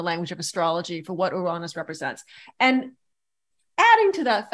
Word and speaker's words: Uranus: which was meeting up with Uranus. That's how I pronounce it. Uranus,